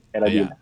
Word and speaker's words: Geradilla: Geradilla. Did I pronounce correctly? Tama ba pagkaka-pronounce ko? Geradilla. Tama? – Tama lang po. Geradilla. Geradilla.